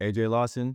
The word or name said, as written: AJ Lawson